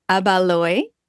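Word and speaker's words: Ibaloi: She is an active Abaloi. Did I say it right? Yes. Warrior that carries her lineage with her every step of her journey.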